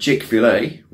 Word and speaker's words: Chick-fil-A: In the USA, KFC, who we all know, has 4,000 something stores, and Chick-fil-A, which is apparently how you pronounce it, has less than 2,000.